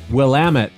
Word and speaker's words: Willamette: Here's, just so you don't get a bunch of calls, it's called the Willamette Valley. – Wait, say it again? – Willamette, damn it. Willamette. That's how you say it.